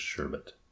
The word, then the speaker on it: sherbet: You're enunciating the E too much. I think it's just sherbet.